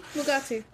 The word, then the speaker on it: Mugatu: Mugatu, yeah. – Yeah. Yeah, okay. I was like, am I saying that right? Mugatu? – Mugatu.